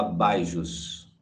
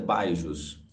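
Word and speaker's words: BYJU'S: Se escreve BYJUS, se fala Baijos, a pronúncia é Baijos.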